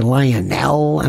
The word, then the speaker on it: Lionel: Prepare with Lionel, L-I-O-N-E-L. Some people can't spell it. They say Lionel, I don't know, two L's or whatever. Lionel rhymes with vinyl.